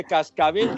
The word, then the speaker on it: cascabel: Oye, oye, está de maravilla con esa salsita de chile cascabel dinamitero, bueno. – ¿De qué? ¿Chile mascabel? – Cascabel, hombre, que no, no pronuncias de manera correcta. Cascabel.